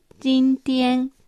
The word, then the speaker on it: 今天: 中国語で今日は今という字に天国の天と書いて、人天と発音します。